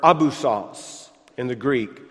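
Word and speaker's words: abyssos (ἄβυσσος): It would just be pronounced abyssos, spelled a little different in the original.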